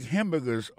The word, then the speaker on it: hamburger: N'a pas remarqué que dans mon hamburger, ça a comme t'as dit, il y a trois syllabes là-dedans. Koto appuie et puis plus ça, c'est sous premier syllabe là.